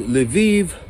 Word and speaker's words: Lviv: So, I'm out here in Lviv, which rhymes with Tel Aviv, because eventually 6 million Jews are gonna come live here.